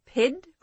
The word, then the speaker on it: pied: A pied crow, you called it? – Right. It's spelled P-I-E-D, but you say pied.